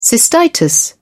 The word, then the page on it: cystitis: British English